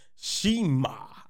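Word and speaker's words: Cima: Not Shima. No, it's Shima. It's pronounced Shima. Everybody, I'm sick of these Americans. I'm sorry, because they did it on AEW too. Look it up. I promise you, it's pronounced Shima. I know it's C-I-M-A, but it's pronounced Shima, people.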